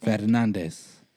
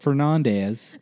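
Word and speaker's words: Fernández: And pronounce that last name correctly, everybody. – Fernandez. – Fernandez. Not Fernandez. If you're going to say it white, just say Fernandez, goddamn.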